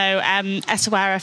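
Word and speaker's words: Essaouira: Writer, and we are going to chat about Ezawira in Morocco. So Ezawira, finally we've got the pronunciation right.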